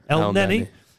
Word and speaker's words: Elneny: Aubameyang, as we said yesterday, has been sleeping on airport floors, but he hasn't got COVID like Elneny has. Is that how you say it? – Elneny.